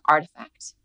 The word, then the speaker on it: RTFKT: Last year, Nike acquired the NFT brand Artifact, which also is spelled R-T-F-K-T.